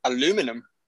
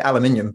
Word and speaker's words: aluminium: So I think my mind's blown how people still say aluminum.